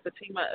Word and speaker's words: Fateima: Now, how do you pronounce your first name? Is that Fatima? – Yeah, Fatima, F-A-T-E-I-M-A.